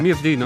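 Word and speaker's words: Myrddin: Myrdin. – Ma sì, così sarebbe la sua pronuncia.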